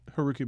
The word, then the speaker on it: Haruki: Haruki Murakami, Haruki Murakami is how I've pronounced it.